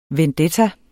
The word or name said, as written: vendetta